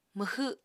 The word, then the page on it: mwxw7: Apatani